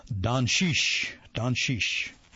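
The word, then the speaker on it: Dončić: His last name is spelled D-O-N-C-I-C with a couple of little things above the two C's, and found out later that it's actually pronounced Doncic, Doncic.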